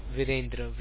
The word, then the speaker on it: Virendra: Virendra, V-I-R-E-N-D-R-A.